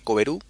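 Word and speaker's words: Coveroo: Por otro lado, eh, tenemos que Coveru se escribe C-O-V-E-R-O-O, -O, e si lo leemos en castellano,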